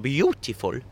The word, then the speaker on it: Biutiful: He was in competition with a film called Beautiful. And I say it like that because it's spelled B-I-U-T-I-F-U-L, because that's how his daughter misspells it.